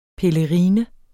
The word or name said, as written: pelerine